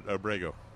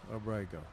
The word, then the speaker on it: Abrego: Oh, Mr. Yes, it is, because we always tend to put the accent on the last syllable. Wrong syllable. David Abrego. – There you go, Abrego.